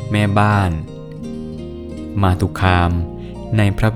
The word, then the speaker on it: มาตุคาม: สวัสดีครับพบกับรายการคำวัดวันนี้ขอเสนอคำว่ามาตุคามคำว่ามาตุคามสะกดด้วยมอม้าสระอาต่อเต่าสระอุคอควายสระอามอม้าอ่านว่ามาตุคาม